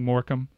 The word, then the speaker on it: Morecambe: So let's, instead of previewing Morecambe, which the B is silent just for all those people listening, let's talk about what's going on in the Premier League, because this is fascinating.